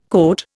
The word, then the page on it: cote: Indian